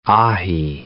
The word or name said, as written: ahi